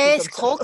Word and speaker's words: escroc: Oui, je crois que ça s'écrit escroc. – Comme ça. – Escroc. – Escroc. – Escroc. Oh, à la fin, le C est muet.